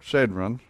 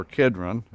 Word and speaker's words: Kidron: he went forth with his disciples over the brook Cedron, or Kidron, is the way it's pronounced today, where there was a garden into which he entered and his disciples.